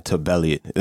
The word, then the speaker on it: Tobelliot: He goes by Tobelliot.